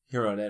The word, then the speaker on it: Girona: Girona.